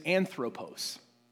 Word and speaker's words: anthropos: The word for self here is anthropos. It sounds like the word anthropology or whatever, but it's like it's a human being.